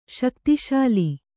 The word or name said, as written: ಶಕ್ತಿಶಾಲಿ